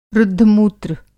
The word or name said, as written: रुद्धमूत्र